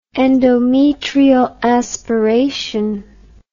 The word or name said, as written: endometrial aspiration